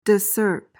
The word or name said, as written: discerp